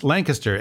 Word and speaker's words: Lancaster: It's Lancaster. It rhymes with Clankister.